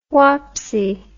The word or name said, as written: whopsy